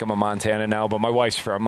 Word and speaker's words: Montanan: Is Montanan the correct? – Montanan.